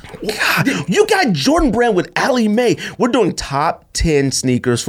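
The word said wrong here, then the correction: Aleali May: That's not how you pronounce her name, but yeah. – Aleli Mae. Aleli Mae 6's.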